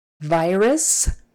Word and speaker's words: virus: Virus, virus. We've all heard a lot about that word lately. Okay, again, V, make sure you pronounce the V and not a B.